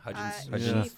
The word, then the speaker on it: Hudgens: Yeah, Hudgens.